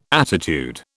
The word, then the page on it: attitude: British English